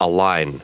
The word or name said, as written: align